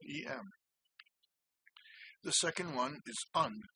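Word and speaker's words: en: And the syllable for low A is written E-N. These, by the way, are all in lowercase. And the pronunciation that I favor is un, but it can also be pronounced n.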